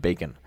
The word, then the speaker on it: BQN: It also has, it can be pronounced, APL sort of has an alternate pronunciation apple. As apple, and yeah. BQN has an alternate pronunciation of bacon.